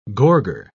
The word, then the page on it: gorger: British English